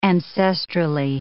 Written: ancestrally

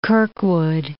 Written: Kirkwood

